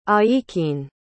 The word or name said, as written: aikin